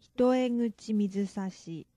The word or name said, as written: hitoeguchi mizusashi